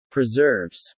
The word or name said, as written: preserves